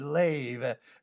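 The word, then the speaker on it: lev (לב): In both the Old Testament, the Hebrew word lev, L-E-V, lev, pronounced as if it were an A, lev, L-E-V, lev, is the heart.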